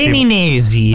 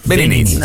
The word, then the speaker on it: Beninesi: O Beninensi. – Beninensi. – Secondo me è più Beninensi. – Beninosi? – Benini. – Benini, ma non benissimo. – Beninesi. – Ah, l'hai detto bene alla prima, vedi? – Beninesi.